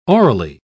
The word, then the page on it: aurally: British English